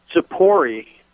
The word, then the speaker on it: Tzippori: The town is 3 miles from Nazareth, and the name of it is, in English letters, Hebrew pronunciation, Tzippori, T-Z-I-P-P-O-R-I.